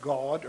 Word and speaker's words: Gad: And there's some pretty good opinion out there that says it should be pronounced God or something like that.